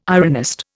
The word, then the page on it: ironist: Indian